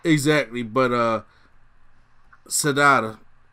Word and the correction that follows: Shida: I'm going to say it wrong until you say it for me. – Shida, just say Shida.